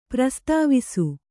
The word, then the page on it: ಪ್ರಸ್ತಾವಿಸು: prastāvisu